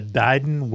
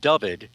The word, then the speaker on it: Dyfed: Okay, you're going to be talking about the Dyden Whales UFO. – Well, actually, it's pronounced Dyved. – Okay. – It's spelled D-Y-F-E-D.